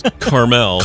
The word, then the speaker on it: Carmel: Carmel. – Carmel? – Mm-hmm.